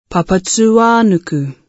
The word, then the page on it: Papatūānuku: Te reo Māori